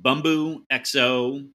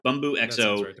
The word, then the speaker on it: Bumbu XO: So it is the uh, the Bumbu XO. I think I'm saying it correctly. – Correctly, yeah. Bumbu XO right uh, rum uh, it is uh, from listener guest, uh, not listener guest, uh, listener Mike Jones, rather.